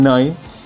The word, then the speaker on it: Neu: Noi, yo, yo lo pronuncié como los Neu. Los Neu, claro, que esos mismos, los Neu, que se pronuncian Noi.